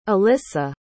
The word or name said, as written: allyssa